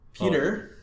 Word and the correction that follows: Petter: oh. Petter, Mrazek. – Yeah. Petter. Petter Mrazek.